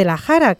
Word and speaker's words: La Jara: La Jara, que pertenecería a su vez a la comarca de Talavera.